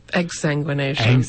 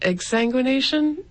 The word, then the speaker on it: exsanguination: Yeah, and on Wikipedia, the first thing I saw that is he died by sanguination. – Exsanguination. – How do you say it? – Exsanguination.